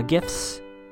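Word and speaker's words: GIF: The Aria Fitz hallway scene, again, I think this was meant for Tumblr uh, gifts. It's a hard G.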